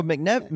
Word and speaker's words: McNevin: Oh, McNevin. – McNevin. Oh, I did mispronounce it. – Yeah. – I didn't even have to try. – You didn't even say a whole syllable. – All right. – It's Brian McNevin.